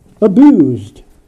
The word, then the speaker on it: abused: Abused, past participle. The S is pronounced as a Z. Abused.